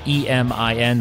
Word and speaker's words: Emin: But if you search for E M I N, that's I believe pronounced Emin, and uh, Fikic, which is F as in Frank, I K I C, you can search for him in Upwork people per hour.